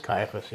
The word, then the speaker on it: Caiaphas: Is it Caiaphas or Caiaphas? – Caiaphas, yeah. – Yes, Caiaphas. So his son-in-law is the high priest.